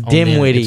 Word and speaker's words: Dinwiddie: Why do I keep wanting to say Whittle? It looks like an L on it's Dinwiddie. – It's witty. – Am I saying Dinwiddie? – No, I'm saying Dinwiddie. You're saying it right.